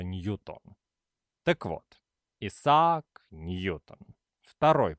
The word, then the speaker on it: Ньютон: На английском языке, а Ньютон был англичанином, его фамилия произносится только так – Ньютон. Никаких вариантов, кроме единственно верного, быть не должно.